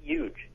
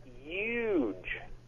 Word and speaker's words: huge: And by the way, you can't say huge. You have to say huge. – Huge. – Yep. – Huge. – You'll love it. – The silent H.